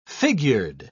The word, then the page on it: figured: British English